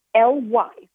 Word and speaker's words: Calendly: If you look for anybody that's familiar with Calendly, it is Calendly. So it's not spelled like calendar.